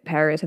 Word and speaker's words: PVRIS: one of them is Paris. I think I told you this. Which is spelled P-V-R-I-S, so the A is upside down for copyright reasons.